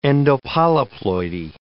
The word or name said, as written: endopolyploidy